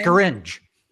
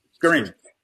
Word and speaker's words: Scaringe: So this is a this is pretty much the, uh, is it Scaringe is how you pronounce this man's name? Scaringe. – Scaringe.